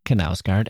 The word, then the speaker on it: Knausgård: Knausgård. And that is how you pronounce it, according to the Knausgård expert with whom I spoke.